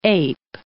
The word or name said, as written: ape